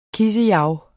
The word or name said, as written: kissejav